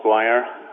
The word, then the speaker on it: McGuire: Hello, my name is Paul McGuire.